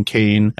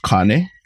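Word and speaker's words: kāne: When you come to Hawaii and you see two signs, one saying cane and one saying wahine, just know that they're very important words. – Right. Okay, so the first one it, it would actually be pronounced kane. Kane.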